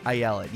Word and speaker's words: Ayelet: Ayelet, yeah.